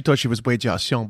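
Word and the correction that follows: Weijia Jiang: but she's Wei Jiajiang. And when in previous episodes, I was mispronouncing her name.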